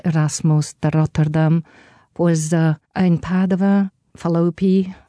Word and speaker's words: Padova: Oh, but Padova is how you say it.